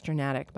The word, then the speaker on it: Journatic: And it is pronounced Journatic, by the way. Timponi says it's a mix of journalism and automatic.